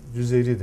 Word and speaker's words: Cezeri: Ahmet-i Cezeri bildiğim kadarıyla zaten Cezeri, Cüzeyri de deniyor.